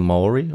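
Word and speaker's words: Māori: Man nennt sie auf Deutsch Maori. – Ah, ja. – Oder in deren Sprache spricht man es ein bisschen anders aus, eher so Maori oder Maori.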